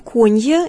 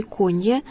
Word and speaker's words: Конья: Это город Конья, явно ударение на первом слоге Конья.